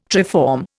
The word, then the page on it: triform: Indian